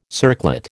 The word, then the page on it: circlet: American English